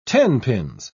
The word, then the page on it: tenpins: British English